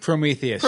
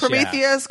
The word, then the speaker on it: Prometheus: Prometheus? Am I saying that right? – Prometheus. – Prometheus, yeah.